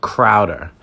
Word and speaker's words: Crowder: I like the 1-2s and 3s, Quincy Enunwa, Robbie Anderson, Chowder. Well, I probably said his name wrong. I know his name is Jameson Crowder. My bad. There you go. Crowder.